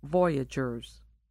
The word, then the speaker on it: voyageurs: The boatmen who traded along the rivers were called voyageurs, pronounced voyageurs.